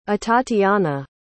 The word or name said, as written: atatiana